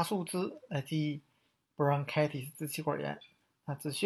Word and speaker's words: bronchitis: 那这个单词呢，在发音的情况，大家注意一下，它这个 t 呀要浊化，读成 bronchitis。